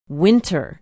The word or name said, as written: winter